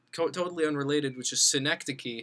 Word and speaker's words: Synecdoche: What other works of Charlie Kaufman have you seen besides just Schenectady and Anomalisa? – It's actually Synecdoche. It's a pun.